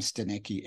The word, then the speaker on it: Stanecki: So my surname is spelled S-T-A-N-E-C-K-I. So if you type in Adam Steneki anywhere, you will find me.